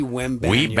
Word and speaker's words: Wembanyama: Just as a refresher, it's not Wembanyama. It's not Kumbayama or Wembanyama. It is simply Wembanyama. – Wembanyama. – Victor Wembanyama.